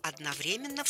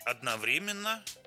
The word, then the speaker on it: одновременно: Русский орфографический, большой орфоэпический, большой толковый и словарь трудностей русского языка разрешают два ударения. – Одновременно и одновременно.